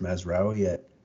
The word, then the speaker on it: Mazraoui: I mean, I think it's pronounced Mazraoui at Morocco.